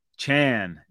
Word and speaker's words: Achane: And I was going to call him Devon Achane, and I had that sweet Van Halen, Achane. Nothing stays the same, Achane. But you hit the ground running. But he let us know that it's Chan, Devon Achane.